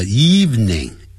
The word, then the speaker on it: evening: 记住不要念成 evening, 叫 evening, eve, 再加一个 ning 的音。好多呢我们老中念成 evening, 不是 evening, eve, 加一个 ning 的音。 evening, evening, 特别要记住。